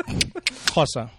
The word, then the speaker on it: Xhosa: Yeah, it's a click. Xhosa.